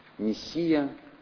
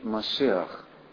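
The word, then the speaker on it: машеах (מָשִׁיחַ): Мы обратимся и к его первообразу этого слова, еврейскому, которое мы привыкли произносить «мессия», но точнее на еврейском языке оно звучит «машеах».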